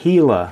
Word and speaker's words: Gila: Well, let's get the pronunciation right there. Uh-oh. Gila. – Gila monster. Okay. – As though it were spelled with an H.